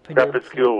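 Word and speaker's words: Crépuscule: Is it Crepuscule with Nelly? Crepuscule, I don't know how to pronounce it. I'm a bad pronouncer. – Crepuscule with Nelly. – Crepuscule, okay.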